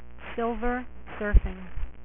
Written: Silver Surfer

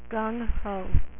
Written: gung ho

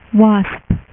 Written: wasp

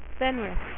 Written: Fenris

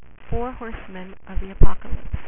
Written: Four Horsemen of Apocalypse

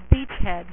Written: beachhead

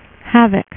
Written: havok

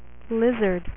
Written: Lizard

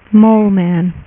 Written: Mole Man

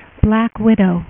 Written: black widow

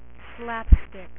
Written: Slapstick

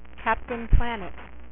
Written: Captain Planet

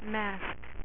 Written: masque

